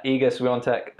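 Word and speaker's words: Świątek: So obviously we had Iga Swiatek, is, I think that's how you pronounce it, even though when you read it, it literally doesn't look anything like that.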